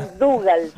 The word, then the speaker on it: Mac Dougall: McDougall.